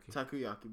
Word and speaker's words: takoyaki: Takoyaki balls, which are octopus balls.